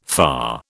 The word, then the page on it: FA: British English